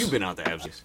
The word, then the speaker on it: Absecon: Absecon? – That one. – Absecon.